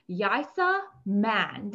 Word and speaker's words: Yaisa Mann: So, without further ado, let me go ahead and introduce Yaisa Mann.